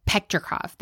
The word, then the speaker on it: Petrikov: As Simon Petrov, no, did I say that wrong? – Petrikov, thank you, moves towards a young Marceline in the flashback to comfort her.